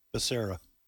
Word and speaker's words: Becerra: There's a story on Fox News right now, and here's what the headline reads HHS Secretary Beccaria. Is that how you pronounce fella's name? Becerra. Becerra. Yes. My apologies.